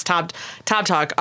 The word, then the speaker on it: Tobtok: I think it's Tob Talk, T-O-B-T-O-K. – Okay. – Is that right? Let me just make sure. Yes, Tob Talk, which featured an artist named River.